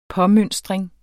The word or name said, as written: påmønstring